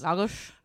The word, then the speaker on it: Lagos: I was in Lagos, Portugal. – Is that how you say it? – Yes. – Lagos.